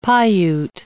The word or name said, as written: Paiute